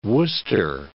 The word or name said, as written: Wooster